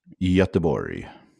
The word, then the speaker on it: Göteborg: But what is it really? It's like Göteborg? – Göteborg. – Yeah. – Yeah. It's Gothenburg, but in Swedish, it's Göteborg.